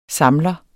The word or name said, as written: samler